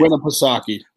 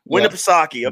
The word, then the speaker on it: Winnipesaukee: Winnipesaukee. – Winnipesaukee, okay.